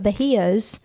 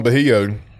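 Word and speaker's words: Bajio: The story on Bahio, if you haven't heard, a lot of you may not even know what Bahio is. It's B-A-J-I-O, Bahio is how it's spelled.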